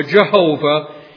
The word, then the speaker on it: Jehovah: I am, or as it's repeated over and over again, the holy name, pronounced either Yahweh in Hebrew or Jehovah.